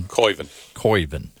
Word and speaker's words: Koivunen: Koivunen. – Yeah. – K-O-I. – C-O-Y is the way I remembered that, but I screwed it up the first time.